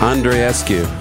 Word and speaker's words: Andreescu: Andreescu.